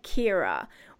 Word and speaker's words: Qhyrrae: The name is Kyra, and it's spelled Q-H-Y-R-R-A-E. – Oy. – She pronounces it like Kyra.